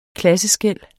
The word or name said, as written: klasseskel